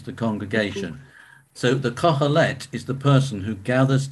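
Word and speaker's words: Qoheleth: The Kohelet, okay? Spelled with a Q, Q-O-H-E-L-E-T-H, the Kohelet.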